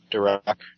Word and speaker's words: Durock: Okay, I was going to put D-U-R-A-Q. – Durock. Durock is how I'm saying it.